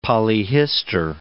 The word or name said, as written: polyhistor